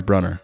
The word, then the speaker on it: Brunner: Nydia Bruner. Am I saying that? Bruner is Brunner.